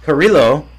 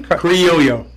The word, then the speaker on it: Criollo: And I'm gonna Cheech and Chong it with the Corojo 99 and the Carrillo. Is that proper pronunciation? – Criollo.